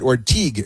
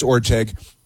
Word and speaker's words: Orteig: And again, they mispronounced it Orteig. It's Orteig, but that's okay.